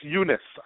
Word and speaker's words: Yunus: Is that how you say his name, Yunus? – It's Yunus, I believe. – Yunus, there you go.